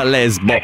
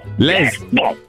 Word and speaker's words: Lesbo: Si scrive l'espollo, ma si pronuncia Lesbo. – Lesbo.